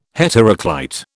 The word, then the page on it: heteroclite: British English